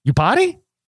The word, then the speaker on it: Iupati: Iupati? – That's exactly how you pronounce it.